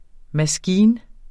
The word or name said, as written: maskine